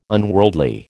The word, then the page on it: unworldly: American English